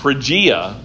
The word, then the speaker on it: Phrygia: it was in the Phrygia area of the Galatia province,